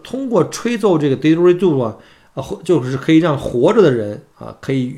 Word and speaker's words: didgeridoo: 其实那个英文或者说它土著人的名字叫做 didgeridoo 啊，大家记住这发音啊，叫 didgeridoo，一种用大木管子制造的一个很长的一个土著的乐器，我看长的得超过两米，呃，是澳洲土著部落的一个传统的乐器，也是世界上最古老的乐器之一。